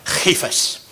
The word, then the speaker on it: Cephas: And Cephas or Cephas. You want to say it right, Cephas. But, or at least that's what we're told that's how it's said.